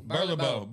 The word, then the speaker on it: Burlebo: I pronounce it different, but Burlebo? – Burlebo. – Burlebo. Burlebo. So, Burlebo has some really awesome stuff.